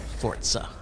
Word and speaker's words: Forza: You remember when we used to say Forza? And then Ruben goes, it's actually pronounced Forza.